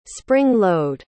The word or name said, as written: spring-load